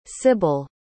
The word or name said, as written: Sybil